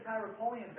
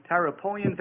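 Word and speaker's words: Tyropoeon: In between the Tyropolean Valley and the Kidron Valley is Mount Zion.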